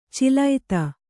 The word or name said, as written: ಚೀಲೈತ